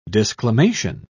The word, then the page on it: disclamation: British English